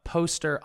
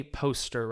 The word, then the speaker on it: Postyr: This is Poster on Talk Acapella today. Now, how do I say poster right? Because it's P-O-S-T-Y-R. What's the proper pronunciation? – In Danish, it's pronounced poster.